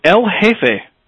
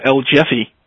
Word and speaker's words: El Jefe: Our first email tonight comes from El Jefe, or for those of you who are pronouncing it America, El Jeffy.